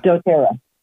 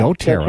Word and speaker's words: doTERRA: Folks, we have a, now am I pronouncing this right, doTERRA or doTERRA? – doTERRA. – Yeah, doTERRA.